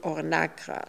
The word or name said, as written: Ornakra